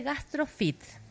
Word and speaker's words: GastroFeat: en la segunda edición de GastroFit, FEAT, se escribe, GastroFEAT, para que después lo busquen en redes.